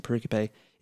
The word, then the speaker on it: pericope: So we would call that the pericope, or some may pronounce it pericope. I've been taught to pronounce it pericope.